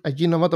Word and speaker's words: Ajinomoto: Allí, no, con J, Ajinomoto, pero se pronuncia Ajinomoto porque una vez fui a,